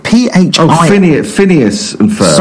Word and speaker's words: Phineas and Ferb: It was called Pinies and Ferb. Is that how you pronounce it? – Phineas and Ferb. – Sorry, absolutely. Phineas and Ferb.